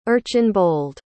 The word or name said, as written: erchanbold